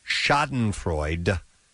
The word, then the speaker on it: Schadenfreude: It says Schadenfreude is how you do pronounce it.